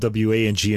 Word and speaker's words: Wang: It's Wang, and I should have said Yuja Wang. Oh. Okay, so my apologies to Ms. Wong for that. She's a great pianist, and I should say her name correctly.